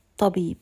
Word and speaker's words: الطبيب: Note that when I say هذا الطبيب, doubling the ta, that means that there is an alif lam at the beginning of this word. So it's written as alif lam ta shadda, and it's pronounced هذا الطبيب.